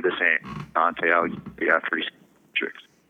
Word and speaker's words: Beatrice: And Beatrice is how they say her name in Italian.